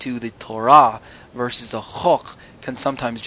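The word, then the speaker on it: chok (חֹק): It looks like it's spelled choke, C-H-O-K-E, but really it's chok.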